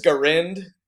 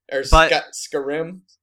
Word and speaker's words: Skyrim: It's pronounced Skarind. – Oh yeah. – Skyrim.